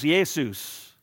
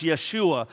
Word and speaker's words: Yeshua: In the Greek language, it would be pronounced Jesus. In the Hebrew language, it would be pronounced Yeshua.